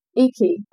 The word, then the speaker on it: iki: Iki. I don't know if you how to pronounce this, it's I-K-I.